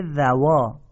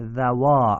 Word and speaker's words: ذوا: اینجا دقت کنید، ذو عدل نیست، ذوا عدل.